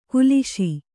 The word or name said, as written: ಕಲುಷ